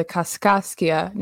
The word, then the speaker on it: Kaskaskia: And I am coming to you from North Canton, Ohio, where I am recording on land belonging to the Kaskaskia Nation. I did look that up, and that is the pronunciation.